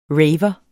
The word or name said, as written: raver